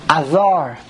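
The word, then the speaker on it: Ador (אדר): The Aleph is swallowed. It's a Chat of Patach. And the Dalet does not have a Dogesh. So the real way to pronounce the month is Chodesh Ador. Ador. Like other, almost.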